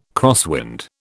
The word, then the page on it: crosswind: British English